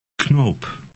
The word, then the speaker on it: knoop: Knoop.